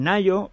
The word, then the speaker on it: Ngaio: Sí, bueno, yo había leído algunas de sus novelas, ella es neozelandesa de origen, en Nayo se escribe Engaio, N-G-A-I-O, Mars.